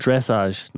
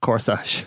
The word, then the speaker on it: dressage: It's called dressage, not corsage.